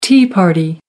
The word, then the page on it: tea party: American English